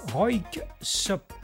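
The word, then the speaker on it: Röyksopp: C'est Roik Sop, difficile à prononcer, Roik Sop, voilà, R-O-Y-K-S-O-2-P, un album sorti en 2001 baptisé Melodyheim, beaucoup plus facile à prononcer.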